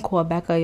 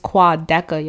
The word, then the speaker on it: Quadeca: It's not even Quadeca, y'all. I said it wrong in the last one. It's Quadeca, y'all. I am sorry.